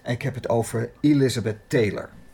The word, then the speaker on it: Elizabeth: Elizabeth, met de klemtoon op de I.